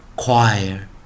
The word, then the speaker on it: choir: 哦，它不算是美呃英语的原生字哦，它有点像法文哦，所以说它是念 w o o 的时候，前面发出个 w w w，来配上 i r 念 i，所以我们在它是其实啊，我们不要看这个 c h 的时候，它是念 wire wire wire，所以合进来是念 choir choir choir，所以这是一个哦，哦。